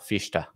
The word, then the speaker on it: Fichte: How do you say his name? – I do not know that much about... I've heard most people say Fichte.